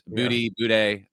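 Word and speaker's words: Budde: But here she is, Marion Boudet of the, and hopefully I'm pronouncing that semi-correctly. – I think you're close. I think that's right. Yeah. – I think I'm close. Boudet, anyway, of the Episcopal Church that is the National Cathedral there in D.C.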